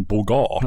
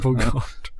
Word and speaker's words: Bogart: Uh, det, det var betoningen Bogart. – Ja, Bogart.